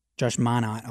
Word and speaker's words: Minott: Josh Minott, I believe, is how it's pronounced. Minott. Yeah, I could be wrong.